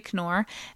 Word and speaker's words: Knorr: I think you pronounce the K actually, Ray Knorr and Tom Gammons will be performing at Dexter Hall, and then they're off to Fiddliness.